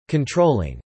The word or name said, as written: controlling